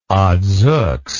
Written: odzooks

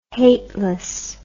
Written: hateless

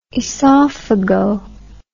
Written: esophago

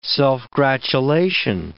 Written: self-gratulation

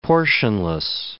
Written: portionless

